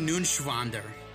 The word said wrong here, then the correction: Neuenschwander: You didn't pronounce it right. That's up. Anthony? – Anthony Nunschwander. – It's N-E-U-E-N. It's Neuenschwander. Anthony Neuenschwander.